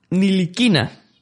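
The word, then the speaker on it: Ntilikina: Y, como muy bien nos dijo David Sánchez, se pronuncia Niliquina.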